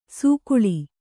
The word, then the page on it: ಸೊಗುಳಿ: suguḷi